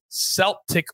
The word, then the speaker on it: Celtic: It's Sunday, 8:30 a.m., Motherwell against Celtic with a soft C. – That's correct, Coach.